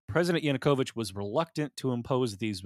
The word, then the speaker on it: Yanukovych: President Yanukovych.